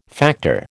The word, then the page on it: factor: American English